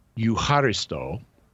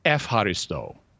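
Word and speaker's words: eucharisto (ευχαριστώ): So to give an example, the word in first century Greek, eucharisto, means thanksgiving, and it's where we get Eucharist, and you would pronounce it eucharisto. But in modern Greek, it's used as a greeting. It means hello, and it's pronounced epharisto.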